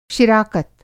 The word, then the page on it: शिराकत: شراکت